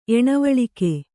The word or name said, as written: ಏಣವಳಿಕೆ